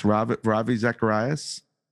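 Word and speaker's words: Ravi Zacharias: excuse me if I butcher this, Ravi Zaccahrias. – Yeah. Yo, geez, perfect.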